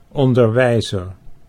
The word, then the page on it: onderwijzer: Dutch